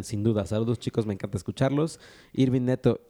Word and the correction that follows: Nieto: eh, creo que lo hizo otra vez. Irving Nieto, pronunciaste mal mi nombre.